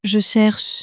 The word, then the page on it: je cherche: French